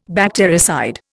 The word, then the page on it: bactericide: Indian